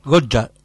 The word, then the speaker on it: Goya: Goya. – Goya. – Así pronuncia correcto. Goya.